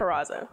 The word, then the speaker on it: Peraza: You said Jessica Peraza. How do you say it? – Peraza. – Peraza? – That sounds like everyone makeup line Perez.